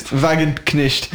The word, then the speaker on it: Wagenknecht: Wagenknecht, je sais pas si je prononce bien. Wagenknecht. – Ouais. Très bien.